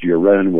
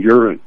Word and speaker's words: Uren: his name was pronounced Uren with the accent on the second syllable. They pronounced his name Uren.